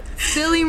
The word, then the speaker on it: Spilly: The name is Spilly, rhymes with Billy. Billy's my real name, bro. You keep calling me Slippy. I don't know who that is. Spilly rhymes with Billy.